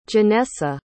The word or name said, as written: ginessa